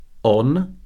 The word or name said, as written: on